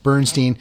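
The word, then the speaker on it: Burnstein: So if you want to check that out, again, the author Eli Bernstein, and it's spelled B-U-R-N, like, ow, I burned myself, Bernstein, S-T-E-I-N, and the book Phrased and Confused, The Curious Meanings of Timeless Questions.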